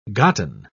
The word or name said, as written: gotten